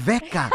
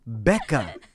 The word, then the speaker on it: Becca: It's like if I called you Becca instead of Becca. Your name is with a B as in boy, not V as in vodka.